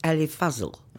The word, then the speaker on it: Fazal: We were saying Ali Fazal. It's Ali Fazal. – Yeah. – Fazal. That's not what you're saying. – Fazal. Well, it's yes. They're both A's, but they're short A's.